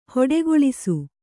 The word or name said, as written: ಹೊಡೆಗೊಳಿಸು